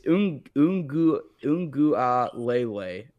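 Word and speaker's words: Uiagalelei: But um, yeah, the only reason I it's Ungu Ungu Lele is how you pronounce it. I'm pretty sure,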